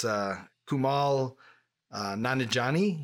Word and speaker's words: Kumail Nanjiani: It's Kumail Nanjiani.